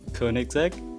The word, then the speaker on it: Koenigsegg: Koenigsegg.